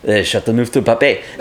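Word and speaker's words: Châteauneuf-du-Pape: Ask a French person how to pronounce Chateauneuf du Pape. And they will say, Chateauneuf du Pape.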